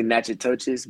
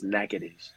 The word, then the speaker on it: Natchitoches: Of people are going to say Natchitoches, but it's Natchitoches. – Natchitoches.